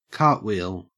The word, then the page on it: cartwheel: Australian English